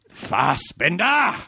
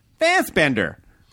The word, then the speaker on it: Fassbender: Yeah. Fassbender. – That's better. – Yeah. – I put the emphasis on the second one instead of the... Well, I mean, I would imagine Americans are like, Fassbender. – That works too.